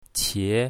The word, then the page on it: qie2: mandarin